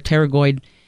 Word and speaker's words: pterygoid: The major muscles that are involved, as we mentioned before, pterygoid, which is a funny spelled muscle, starts with a P, a silent P there, pterygoid,